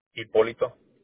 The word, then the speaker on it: Hipólito: It's going to be Hipólito.